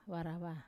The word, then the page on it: varava: Wamesa